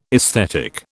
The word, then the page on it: esthetic: British English